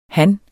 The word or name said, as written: han